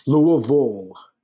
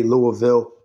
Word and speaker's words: Louisville: How do you say it? Can you say it for us? – Louisville. – Now, you're from Philadelphia, so you don't really have that Southern accent. No, so, but I screw it up all the time and say Louisville, and I catch it from the locals around here, and they correct me, and it's annoying.